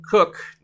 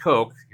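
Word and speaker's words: Koch: As where Sam Cook, not Coke, even though it's spelled like that, he does punt the ball a lot.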